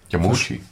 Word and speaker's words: Yamauchi: Yamauchi.